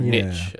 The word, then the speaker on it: niche: Be a real niche, or niche, as the Americans say. – Niche. – Have you noticed this? They say niche. – I have noticed this. Much like herb. – Herbs. – And I am not having any of it. Niche. – No, herb is not a silent H. – Respect the French. Respect niche.